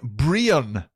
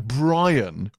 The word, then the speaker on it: Brian: You all got Donal pretty right, but Brian Gleeson pronounces his name Brian rather than Brian, which is the more traditional Irish language version.